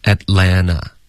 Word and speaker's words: Atlanta: 在美式的发音中两个 T 都肯定省略。 – 那会读成什么呢？ – Atlanta。